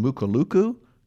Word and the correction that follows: Mulukuku: you know, Nicaragua, is that the name? – Mulukuku. – Mulukuku. Okay.